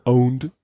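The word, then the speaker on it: pwned: Ah ja, den Begriff owned, auch mit P geschrieben, spricht man owned trotzdem aus.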